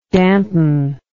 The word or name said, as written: dantonesque